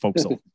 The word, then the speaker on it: forecastle: But it's pronounced forecastle.